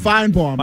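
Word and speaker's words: Finebaum: How do you say his name? Paul Feinbaum? – Feinbaum. – Right.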